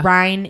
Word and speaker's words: Rhine: The Rhine is in Germany. – Okay. – Like Rhine rhymes with Ein, which is like Ein, yes. Fein, dry.